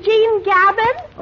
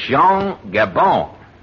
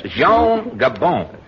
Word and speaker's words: Jean Gabin: Oh, you mean Jean Gabin? – Oh, not Gabin. It's Jean Gabon. – Gabon? – Yes, Jean Gabon. You pronounce it through the nose, you see.